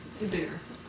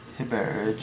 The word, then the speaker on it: Hebert: Hebert. – Hebert. It's H-E-B-E-R-T.